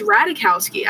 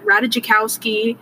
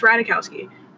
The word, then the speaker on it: Ratajkowski: First of all, can we talk about how to pronounce her name? It's Ratajkowski. I don't know why people, like, struggle so hard. They're like, Ratajkowski.